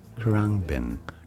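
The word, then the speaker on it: Khruangbin: Karangbin.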